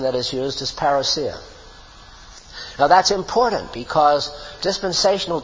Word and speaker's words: parousia: You hear people saying parousia, that's all right, you know, but the Greek pronunciation is parousia.